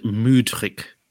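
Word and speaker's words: Mudryk: Is it Murdoch? Murdoch? – Apparently you're supposed to pronounce it Mudrick. – Okay, I'm not going to say. – Apparently it's like, I've been pronouncing it Mudrick. Apparently it's like Mood.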